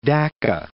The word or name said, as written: Dacca